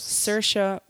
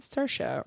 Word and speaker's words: Saoirse: Saoirse Ronan. – Saoirse Ronan. – I never would have known that. – I saw her on a late night show where she said, Saoirse rhymes with inertia, and I will never forget.